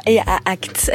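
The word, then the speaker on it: IA Act: Donc c'est vrai que ça peut être complexe euh, à mettre en place justement cette euh, IA axe, comme c'est tout aussi complexe d'ailleurs à prononcer, mais ça, Marie Turcan le fait très bien, elle vient de nous rejoindre dans ce studio. Bonsoir Marie. Bonsoir, bonsoir. IA acte, IA acte.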